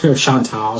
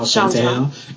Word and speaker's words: Shantel: Chantal. Chantel. It's spelled S-H-A-N-T-E-L.